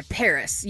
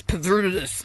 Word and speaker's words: PVRIS: I picked a band that I've liked for a while, but this song is brand new by Paris. You might know them. – Paris Hilton? – By the way, they spell it P-V-R-I-S, so people might think, oh, maybe it's Pavrudis. It's not.